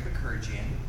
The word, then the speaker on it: Bakerjian: My name is Craig Bakurjian. That's spelled B-A-K-E-R-J-I-A-N.